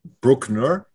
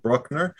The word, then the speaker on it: Bruckner: We will be doing another Bruckner episode, and I am pronouncing it properly this time, reining in how anglophonic I am and actually pronouncing it the way it should be pronounced. It's Bruckner, not Bruckner.